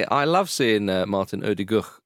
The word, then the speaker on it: Ødegaard: Ødegaard.